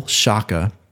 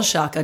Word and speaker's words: Sciacca: It's spelled Sciacca, just like it sounds.